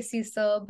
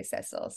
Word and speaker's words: Cecil: Yes, Americans say Cecil, but in England you'd say Cecil.